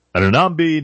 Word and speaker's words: Anunoby: Anunoby, Nembe, OG Anunoby, folks.